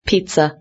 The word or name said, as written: pizza